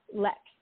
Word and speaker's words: LX: It's spelled LX, but it's pronounced Lex.